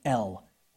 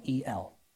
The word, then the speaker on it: El: But there's another god named El. We would spell it E-L, okay? El. He was believed to be the father of Baal, okay?